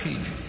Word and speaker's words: Cajun: In English, you pronounce that Cajun.